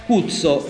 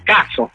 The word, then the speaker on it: Kutso: Ma allora, Cuzzo che si scrive K-U-T-S-O, le nostre intenzioni va letto all'inglese, ovvero in inglese la U spesso diventa A, quindi non è cuzzo ma è cazzo.